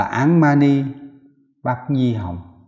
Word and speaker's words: Án Mani Bát Nhi Hồng: Phát âm là văn của tiếng là văn tạng, tiếng Tây Tạng, là Án Mani Bát Nhi Hồng.